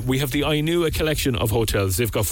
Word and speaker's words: Inua: We're giving you a chance to win a gorgeous one-night getaway in one of the Ainua four-star hotels. You'll find them online. It's spelled I-N-U-A, and it'll bring you to the Ainua collection of hotels right across Cork.